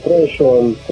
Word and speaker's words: Threshold: esta ocasión vamos a charlar un ratito con uno de los integrantes de Oblivion Threshold. ¿Es así como se pronuncia? – Sí, Threshold.